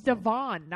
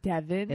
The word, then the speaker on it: Devoin: His name is spelled Devoin or whatever. Like, I'm just not used to it being pronounced that way. It's Devon, not Devin.